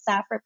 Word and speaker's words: Saphera: It's Saphera Peters. And it's kind of, my name is spelled S-A-P-H-E-R-A and then Peters.